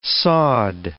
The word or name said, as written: sawed